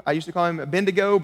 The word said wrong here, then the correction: Abednego: but I don't think that's actually got all the syllables in it. We're going for Abednego.